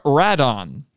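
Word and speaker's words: Radon (ラドン): Well, let me tell you, in Japan, they call him Radon. – Mm-hmm. – Which is a little bit weird when you're hanging out with a bunch of Japanese guys and they're saying Radon and everybody else is saying Rodan.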